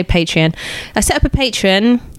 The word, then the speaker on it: Patreon: And I set up a, I can't pronounce this word, a, a, a Patreon. – Patreon. Yeah, I call it really? Patron because I'm a chav.